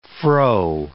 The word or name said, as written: frow